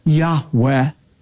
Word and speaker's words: Yahweh: whom Semitic scholars believe is pronounced a breathy, reverent Yahweh.